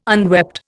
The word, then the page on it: unwept: Indian